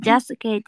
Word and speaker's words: jasuke: Jagung is corn, susu is milk, keju is cheese, and jasuke just coincidentally sounds it rhymes with Sasuke's name.